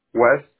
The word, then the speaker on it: West: West. – W-E-S-T, correct? – Yes, ma'am.